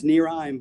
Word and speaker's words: Nereim: It's pronounced Nereim, but...